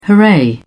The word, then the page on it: hooray: British English